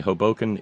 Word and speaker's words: Hoboken: Jen tells me Hoboken is, it's. See, I even say it like Bugs.